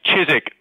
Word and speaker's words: Chiswick: It's not pronounced with a W. It's Chiswick. There is no W. Well, there is a W in it, but you don't pronounce it.